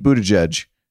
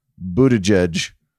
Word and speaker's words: Buttigieg: Let's talk about Pete Buttigieg. And yes, you're going to hear me overpronounce his name a bunch because I'm still getting used to this. Buttigieg. Buttigieg.